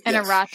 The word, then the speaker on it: Iñárritu: and I hope I get his name right, Alejandro Gonzalez Iñárritu. Iñárritu, I think? – Iñárritu. Sure. I'm pretty sure you had it right the first time.